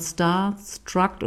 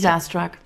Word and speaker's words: starstruck: starstruck oder wie spricht man das richtig? – Starstruck. – Ja, Starstruck.